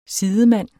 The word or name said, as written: sidemand